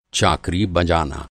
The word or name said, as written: चाकरी